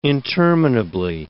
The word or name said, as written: interminably